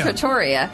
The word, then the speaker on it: Trattoria: Trattoria? How do you say Trattoria.